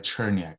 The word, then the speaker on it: Cherniak: And just like when we started, my last name is uh, Cherniak.